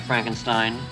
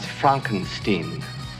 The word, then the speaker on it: Frankenstein: I have one question, Dr. Frankenstein. – That's Frankenstein. – I beg your pardon? – My name, it's pronounced Frankenstein.